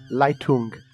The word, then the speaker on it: Leitung: Leitung.